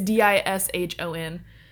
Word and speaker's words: Dishon: It's D I S H O N.